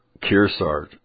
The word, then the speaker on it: Kearsarge: Kearsarge, you said it right.